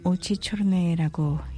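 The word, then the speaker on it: 오치초르네 (Очи чёрные): 검은 눈동자, 러시아 말로는 오치초르네라고 읽는군요.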